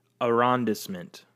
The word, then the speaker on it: arrondissement: Arrondissement. – So, a French department for local government administration, a district of certain large French cities, in particular Paris. So, an arrondissement is, think of like New Orleans, Charlotte.